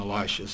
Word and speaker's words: Elisha: His successor's name is Elisha, we'll call him Elisha, so that's kind of rhymes with Elijah.